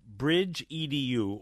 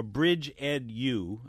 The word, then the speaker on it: Bridge EdU: And here Wes is the founder and CEO of Bridge EDU, or Bridge EdU, I think, which is how it's pronounced. It's capital B, capital E, capital U, Bridge EdU.